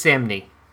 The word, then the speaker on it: Samnee: How do you say his name? – I think it's Samnee.